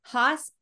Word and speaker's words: Haas: Haas, H A A S, is that's how it's spelled.